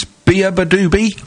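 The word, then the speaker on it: beabadoobee: Was you classy chilled out tune there from the band whose name I can never pronounce. Let's have a go. It's Bia Badoobie. – I got it right.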